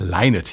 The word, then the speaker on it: Lynott: Die ARD-Aussprachedatenbank hat sich bei der Aussprache an den Kollegen der BBC orientiert. Und die Damen und Herren auf dieser Insel sagen laut BBC-Aussprachedatenbank Lynott hier zu hören.